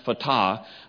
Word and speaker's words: Fatah: You may remember a couple of years ago, we saw on the news that Gaza had elections, and that the ruling Fatah, that's it's pronounced Fatah, but it's you don't care how to spell, and I can't spell it.